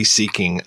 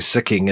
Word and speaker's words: Sikking: Sikking a lot of uh, room to play in this, and I love him. Or sorry, Sikking is actually how you say his name.